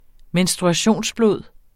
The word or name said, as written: menstruationsblod